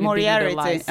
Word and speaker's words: Moriarty: Alltså, fan jag, nu kommer mitt dåliga engelska uttal fram, men Liane Moriarty, hon som har skrivit Moriarty.